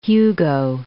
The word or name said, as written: Hugo